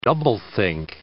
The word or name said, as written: doublethink